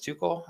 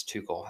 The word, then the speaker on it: Tuchel: Haven't lost under their new head coach, Thomas Tuchel, I believe is how you pronounce his last name, T-U-C-H-E-L.